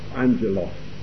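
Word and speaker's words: Angelos (ἄγγελος): In the Greek, I'm never sure quite how to pronounce it, I think it's agalos, is it? – Angelos. – There we go.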